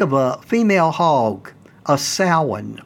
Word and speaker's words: Samhain: And the M is silent in Samhain, a word that's actually spelled S-A-M-H-A-I-N. I know the first time I saw that word, I pronounced it totally wrong, way off base.